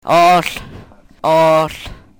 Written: oohl

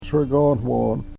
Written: sregonwon

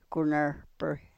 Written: ku nrhpry

